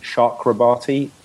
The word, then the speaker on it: Chakrabarty: I'm the only actor on the show that says his own character name wrong, because apparently it's Ravi Chakrabarty. Chakrabarty.